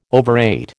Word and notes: overrate: American English

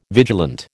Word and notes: vigilant: American English

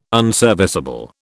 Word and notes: unserviceable: British English

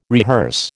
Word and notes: rehearse: American English

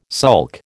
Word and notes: sulk: American English

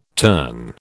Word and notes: turn: British English

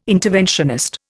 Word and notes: interventionist: Indian

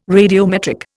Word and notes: radiometric: Indian

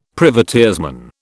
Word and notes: privateersman: British English

